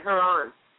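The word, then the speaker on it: Joran: Haran is his name, by the way. Haran. – Really? – It's Haran. I pronounce it the American way.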